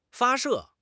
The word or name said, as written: 发射